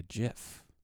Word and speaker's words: gif: and then his campaign staff like replied with like a gif, which is how it's pronounced, Paul.